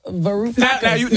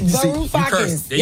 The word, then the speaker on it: Varoufakis: Varoufakis. – Now they on stage. Now they mad because you said their name wrong. – You cursed on the radio 3 times already. – Varoufakis.